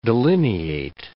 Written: delineate

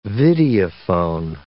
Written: videophone

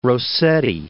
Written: Rossetti

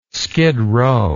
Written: skidrow

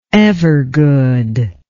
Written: evergood

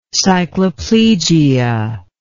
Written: cycloplegia

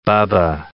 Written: Bubba

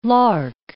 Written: lark

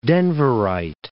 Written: Denverite

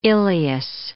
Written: ileus

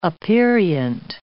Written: aperient

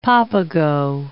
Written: Papago

